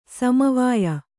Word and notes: ಸಮವಯ: sama vaya

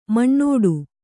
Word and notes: ಮಿನೀಟು: minīṭu

